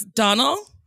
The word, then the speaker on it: Domhnall: I switched the H and the N, so it's Donal.